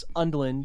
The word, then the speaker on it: Undlin: Like the first example is Cory, I think it's pronounced Undlin,